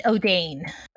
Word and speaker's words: Odayne: In the audiobook, they pronounced it Odane. – Okay. – How is it spelled? – O-D-A-Y-N-E.